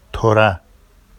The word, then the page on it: tora: Vafsi